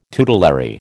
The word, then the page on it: tutelary: American English